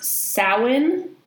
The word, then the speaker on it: Samhain: And the first known celebration of Halloween was an ancient Gaelic festival in, now it's pronounced Samhain, but it's S-A-M-H-A-I-N.